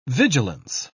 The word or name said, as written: vigilance